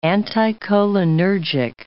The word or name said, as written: anticholinergic